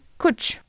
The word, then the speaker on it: cwtch: Il s'écrit C-W-T-C-H. Attends, mais euh, on le prononce comment ça Ça se dit coach.